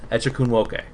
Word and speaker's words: Echikunwoke: She will continue to, actress Megalyn Echikunwoke, as that's how you pronounce it. I apologize. Echikunwoke. There we go.